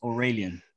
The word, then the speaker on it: Aurelian: Mr. Aureline, Aureline, Aureline, Mr. Clean, what is he? I believe it's pronounced Aurelian or Mr. Aurelian Smith Jr., born May the 30th, 1955, making him 56.